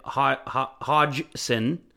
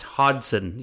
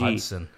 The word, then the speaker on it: Hodgson: he goes, by the way, it's pronounced Hodgson. You don't pronounce the G. – Hodgson.